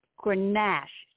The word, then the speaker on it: Grenache: The announcement was, it's Grenache is the proper pronunciation.